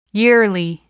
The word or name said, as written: yearly